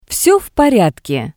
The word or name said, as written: Vse v poryadke